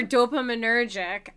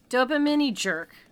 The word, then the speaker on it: dopaminergic: Because the word before dopaminergic is mesocorticolimbic. – Uh-huh. – So, like, I'm not only doing fucking gymnastics over dopaminergic.